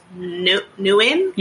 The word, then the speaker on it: Nguyen: There's a quote from Henry, I'm gonna pronounce his name as Nguyen. – Yeah. – Okay. – Sounds right to me.